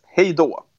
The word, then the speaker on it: hej då: Written in Swedish, it says, hej då.